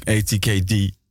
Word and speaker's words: ATKD: Je speelt bij Benjamin Froh, Maddy Morea, ATKD, of moet ik het Engels uitspreken? – Eigenlijk wel, ja. – ATKD.